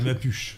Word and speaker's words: Mapuches: Avec l'accent tonique sur le U. Euh, en espagnol, alors en français on dirait les Mapuches.